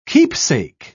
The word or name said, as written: keepsake